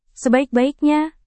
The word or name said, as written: sebaik-baiknya